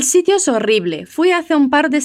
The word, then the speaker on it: horrible: Es horrible. Com esses dois R's vibrantes e fortes mesmo. Es horrible.